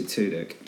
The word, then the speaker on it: Tudyk: I should just say Tudyk. – Tudyk? – Because it sounds like two dicks, and that makes me chuckle.